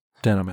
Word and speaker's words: Denholm: No. No, you need to make it sound natural and Denholm. Fast.